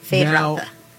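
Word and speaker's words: Feyd-Rautha: Fade Rautha.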